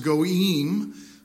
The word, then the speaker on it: goyim: This is in the Hebrew, it's pronounced goyim.